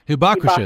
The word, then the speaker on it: hibakusha: It was titled, "Let There Be No More Hibakusha is how you pronounce it.